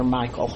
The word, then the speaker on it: Michal: They're named in Scripture, and I'm a little insecure about my Hebrew pronunciation, so I'll probably anglicize every pronunciation today. So it may be Michal or something like that, but I'm calling her Michael, okay?